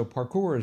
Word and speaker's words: parkour: And it is from the French, so parkour is probably the right way to pronounce it. – Huh. – It doesn't look French with that K in there, though.